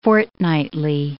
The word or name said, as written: fortnightly